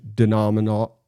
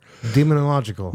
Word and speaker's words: demonological: While two distinctive and often rival trends tied to this distinctive etiologies of corruption and latter Jewish and Christian denominological lore. – Demonological.